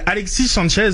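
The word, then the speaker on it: Alexis Sánchez: C'est pas un ami de l'hispanophone, mais Alexis Sanchez, comme sans la prononciation du S, c'est généralement ce que j'ai entendu.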